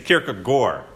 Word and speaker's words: Kierkegaard: But it's in Danish, it's Kierkegaard, which is just really funky.